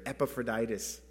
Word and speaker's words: Epaphroditus: Epaphroditus.